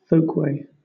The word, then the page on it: folkway: British English